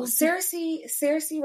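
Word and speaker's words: Saoirse: So Cersei Ronan, because I had to look up how to say her name.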